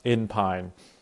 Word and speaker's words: Inpyn: She is, her last name is spelled I N P Y N, and it's pronounced Inpine.